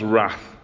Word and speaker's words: wrath: Well, at least an R sound, because the first one starts with a silent W. So three R's. The first one is God's wrath. There's the W, wrath.